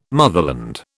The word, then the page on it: motherland: British English